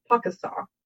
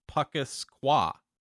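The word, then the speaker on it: Pukaskwa: But, so on the very top northeastern edge of Lake Superior, there's a giant Canadian national park called Puckasaw. – Puckasaw. Oh yeah, Puckasaw. Puckasqua. – They call it Puckasaw. – But so the K is silent. – Yes. – The second K is silent in Puckasaw.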